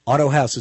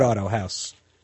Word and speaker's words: Autohaus: Auto House is spelled AUTOHAUS, the German way. Import Auto House.